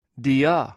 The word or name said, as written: dio